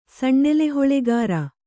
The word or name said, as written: ಸಣ್ಣೆಲೆಹೊಳೆಗಾರ